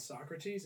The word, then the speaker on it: Socrates: Socrates and she pronounced it Socrates and I was like, I just can't look at you the same.